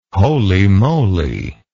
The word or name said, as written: holy moly